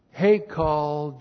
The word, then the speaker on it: Hekal: Three, and they will bring gifts to build Hekal Jehovah. They will bring gifts to build Hekal, and then the tetragrammaton. That's H, long E, K, A, L, and then the tetragrammaton.